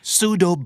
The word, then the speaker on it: pseudo: pseudo